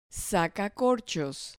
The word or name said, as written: sacacorchos